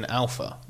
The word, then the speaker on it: Alfa: It's just it's just annoying because for Alfa Romeo, it's always been Alpha. Like that is how you say it.